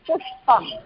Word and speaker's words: पुष्पम्: These words are for neuter gender, so pronounce properly pushpam.